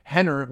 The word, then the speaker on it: Rener: But I'm you can't even call him Rener with an R, pronounced with an H.